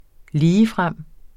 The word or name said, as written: ligefrem